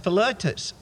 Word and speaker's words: Philetus: Hymenaeus and Philetus. I'm not sure, did I pronounce that right, Pastor Bruce? Yeah, oh good.